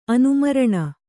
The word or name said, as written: ಅನುಮರಣ